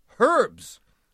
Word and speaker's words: herbs: And there is an H pronounced resonantly at the beginning of our herbs.